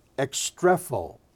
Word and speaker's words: ekstrepho: It's translated from the Greek word ekstrepho, ekstrepho.